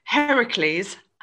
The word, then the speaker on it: Heracles: Okay, so apart from the 12 labors, Heracles, I said it right this time.